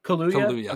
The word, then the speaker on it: Kaluuya: How do we pronounce it? Because I'm not sure. Is it Daniel Kaluuya or Daniel Kaluuya? – Kaluuya. – Okay. Kaluuya.